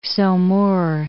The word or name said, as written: Saumur